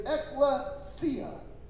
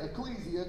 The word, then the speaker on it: ekklesia: And I used to say ecclesia because that's the way it sounds and the way it looks like it's spelled to me. But since Pastor Brett Baggett's church is called Ekklesia Church, I probably ought to say it right so I don't offend him, okay?